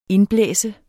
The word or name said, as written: indblæse